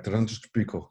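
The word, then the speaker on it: Terrantez do Pico: And the name is Terrantes do Pico. They would pronounce it much faster, like Terrantes do Pico, something like that.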